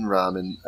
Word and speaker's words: ramen: Ramen is how you say it in Korean.